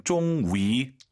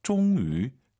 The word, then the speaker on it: 终于: 자, YU 발음 쫑위 다 알아들어요. 근데 입술을 동그랗게 말아서 쫑위, 쫑위 한번 해보세요.